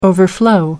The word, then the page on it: overflow: American English